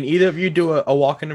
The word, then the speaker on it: Walken: Christopher Walken has joined the cast of Dune 2. Walken. Christopher Walken. Walken. – Walken. – Walken. – Walken. – Walken. – Walken. Nobody pronounces the L.